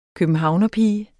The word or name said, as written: københavnerpige